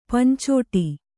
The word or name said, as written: ಪಂಚೊಡೆ